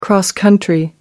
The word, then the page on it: cross-country: American English